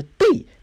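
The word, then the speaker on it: Dei: 然后呢，这个神中国的神奇女侠也特别有意思啊，这个中国神奇女侠的名字叫做彭黛兰，就是它的发音很奇怪，不是我读的有问题啊，它的发音叫 P E N G 没问题啊，然后 D E I D E I 就是 day。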